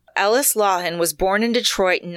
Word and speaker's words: Lauhon: His name was Ellis Lauhan. And I don't know that I'm saying that right, but it's spelled L-A-U-H-O-N, Lauhan.